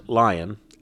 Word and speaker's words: Lyon: This is Dave Lyon, pronounced Lyon. – L-Y-O-N? – Pronounced L-I-O-N.